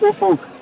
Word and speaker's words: Ufuk: How do we say his name correctly? – It's Oof Oof, but we all call him Oofy to sort of save the embarrassment. Oofy. – Has anyone ever said the magic word? Because it's spelt U-F-U-K.